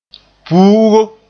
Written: puugo

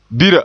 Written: bira

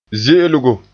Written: ze'elgo

